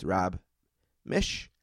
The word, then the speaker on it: Miech: He always tells me it rhymes with wish. It's spelled weird, M-I-E-C-H, but it's Rob Mish.